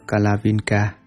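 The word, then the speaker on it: kalaviṅka: Chú thích: Tần già, nguyên tiếng Phạn Ấn Độ đọc là Kalavinka, dịch âm ra chữ Hán là Ca Lăng Tần già, là tên con chim ở núi Tuyết Sơn, Himalaya.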